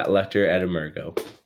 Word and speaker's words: Luctor et Emergo: Is it Lecter Emargo or yeah, Struggle Le- or Emerge? Yeah, Luctor et Emergo.